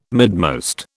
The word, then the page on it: midmost: British English